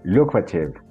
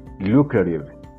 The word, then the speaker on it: lucrative: The word of the day for December the 7th, according to the Merriam-Webster Dictionary, is lucrative. Yes, lucrative. However, in American English, you're going to hear, very likely, lucrative. Lucrative. This is because of the manner on which Americans pronounce that three different T's in terms of sounds. They say that real, the true T, the stop T, and the flapped T.